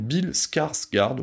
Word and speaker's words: Skarsgård: incarné par cette trogne de Bill Skarsgård, alors je crois que je le dis bien, je vais le répéter quand même, Bill Skarsgård, oui oui, ça se prononce comme ça,